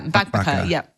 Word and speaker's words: Bakbaka: Confident that I will pronounce this correctly, but it's the One Pan Bakbaka. – Yeah, Bakbaka. Yeah, that's it. You did well.